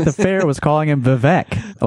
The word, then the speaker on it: Vivek: I think we pretty much nailed it with our prediction that Vivek... It's Vivek rhymes with cake. We've been mispronouncing really? – It.